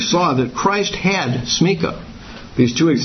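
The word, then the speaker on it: smikhah: I'm pronouncing it smika. Smika.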